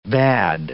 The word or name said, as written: bade